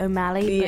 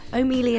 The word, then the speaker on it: O'Meally: Some people do say O'Malley, but O'Mealy is preferred.